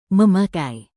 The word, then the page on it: memakai: Indonesian